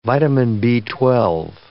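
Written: vitamin B12

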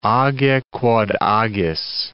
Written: age quod agis

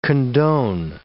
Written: condone

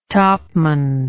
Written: topman

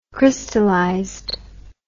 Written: crystallized

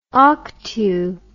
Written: octu

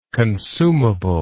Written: consumable

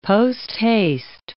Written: posthaste

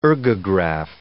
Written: ergograph

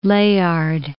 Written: Layard

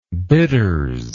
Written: bitters

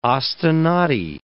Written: ostinati

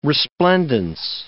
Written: resplendence

